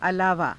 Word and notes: alaavaa: Hindi

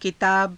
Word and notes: kitaaba: Hindi

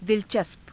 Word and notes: dilacaspa: hindi

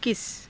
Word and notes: kisa: Hindi

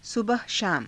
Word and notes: sabaha shaama: Hindi

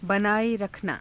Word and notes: banaae_rakhanaa: hindi

